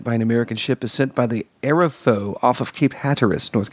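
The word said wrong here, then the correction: Arapahoe: It's Arapaho. I know how to pronounce things.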